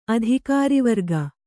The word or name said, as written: ಅಧಿಕಾರಿವರ್ಗ